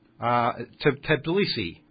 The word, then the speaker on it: Tbilisi: Tbilisi, I think, is what you're trying to say.